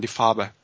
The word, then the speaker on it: Die Farbe: En alemán, Die Farbe.